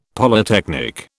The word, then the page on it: Polytechnic: British English